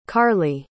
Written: karley

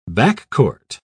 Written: backcourt

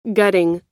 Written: gutting

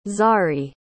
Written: zari

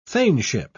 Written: thaneship

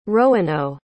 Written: roano

